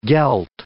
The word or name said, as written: gelt